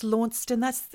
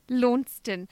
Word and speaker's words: Launceston: Launceston or Launceston? – Launceston.